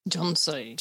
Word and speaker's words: Johnso: Johnso. – Johnso. I thought it was a typo, but it's repeated every time. – It's like Johnson, but with no N.